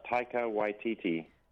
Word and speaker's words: Taika Waititi: His name is pronounced Taika Waititi. – Taika Waititi, yeah.